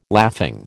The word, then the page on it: laughing: American English